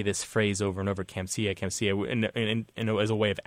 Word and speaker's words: Kamsia: and it's transliterated into Roman characters as Kamsia, K-A-M-S-I-A, with a long A.